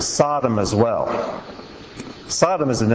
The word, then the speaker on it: Sodom: S-D-M. Sodom is how it's pronounced in Hebrew.